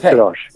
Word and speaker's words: Sirosh: Yes, Joseph Sirosh.